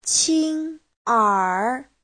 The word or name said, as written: qin1er3